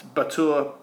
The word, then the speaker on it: Batur: The way I pronounce that might make it hard. It's Batur, B-A-T-U-R.